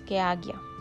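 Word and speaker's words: Ajna: Vamos ya con los últimos dos, vamos con el sexto chakra que es el chakra de la ceja o del tercer ojo, acna. Se pronuncia Agia. Dios mío, yo pronunciándolo acna.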